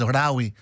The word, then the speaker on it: Lawi: He calls himself simply Rawi, L-A-W-I, Rawi, as in Malawi, but you cut the M-A. So Lawi, or pronounced, of course, Rawi, and it's called Timarira.